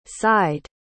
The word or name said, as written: seid